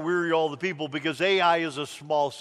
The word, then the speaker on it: Ai: is sent down to Ai, is how I pronounce it, down to Ai, which is the next conquest.